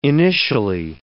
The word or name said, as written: initially